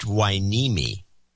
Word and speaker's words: Hueneme: It's Port Hueneme. – Oh, I can't believe you blew that. – Yeah, well, I got to know because I pronounced it. What did I say? Hunemi. – Hunemi. – It's Hueneme.